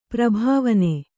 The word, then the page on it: ಪರಿಭಾವನೆ: pari bhāvane